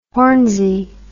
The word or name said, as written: hornsey